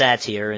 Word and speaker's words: Satir: I have a quote here from a Virginia, I believe the name is pronounced Satir, it's S-A-T-I-R.